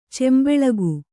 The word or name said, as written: ಚೆಂಬೆಳಗು